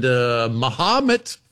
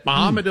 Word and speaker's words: Mahomet: I'm doing Vincennes and Columbus, Indiana, and Mahomet. Finally learned how to say it. Mahomet, Illinois.